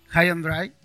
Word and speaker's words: High and Dry: Él produjo High and Dry, ¿está bien pronunciado? Sí, si no me un choto.